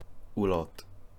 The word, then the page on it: Olot: Catalan